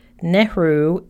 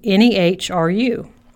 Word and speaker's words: Nehru: Last name is Nehru, Nehru.